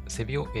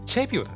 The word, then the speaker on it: チェビオ: えー、で、え使い方、セビオ AI。セビオ、これセビオ AI で合ってんのかな、読み方。ちょっと、ちょっと一旦調べていいこれセビオ AI で合ってんだよね、読み方ね。あ、ごめん。セビオじゃない。チェビオだって、チェビオ。